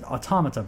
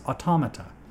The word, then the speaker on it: Automata: Automata, by the way. It's been out for long enough. I said it wrong. I said it wrong a long time ago. But before the game came out, I realized it's Automata. Some people are still getting it wrong.